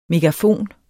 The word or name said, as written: megafon